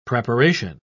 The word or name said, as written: preparation